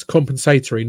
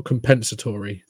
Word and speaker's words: compensatory: I get really annoyed how Americans pronounce that word. It's compensatory, not compensatory. Come on.